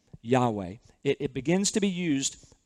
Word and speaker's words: Yahweh: That name that comes over, it's four Hebrew letters that we best know to pronounce as Yahweh.